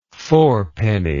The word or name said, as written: fourpenny